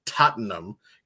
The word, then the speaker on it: Tottenham: I hope I get the pronunciation correct, Tottenham. You're not supposed to pronounce the H apparently.